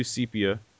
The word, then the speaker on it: sepia: Hold on, let's do sepia. So, like, sepia tone? – Yeah. – S-E-P-I-A. Sepia. It's three syllables.